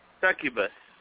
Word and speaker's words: Succubus: S-U-C-C-U. What is it? – Succubus.